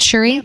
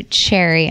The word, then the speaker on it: Cherie: Cherie is it Cherie? – Got it right. oh, okay. I was like, I was gonna say Cherry.